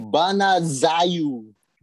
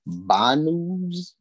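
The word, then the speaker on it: Bună ziua: Banazayu do, folks. That is how you say hello in Romanian. Banazayu? B-U-N-A space Z-I-U-A.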